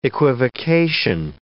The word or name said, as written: equivocation